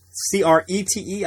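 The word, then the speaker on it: Crait: C R E T E, I think is the name. Maybe it's C R A T. I'm not sure. Crete is how it's pronounced.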